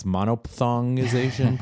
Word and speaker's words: monophthongization: monothongization. – Monothongization. – Yeah, well, there's a PH in there, so I'm not sure I'm pronouncing it right, but it's like diphthong, so I guess it's monophthongization, probably.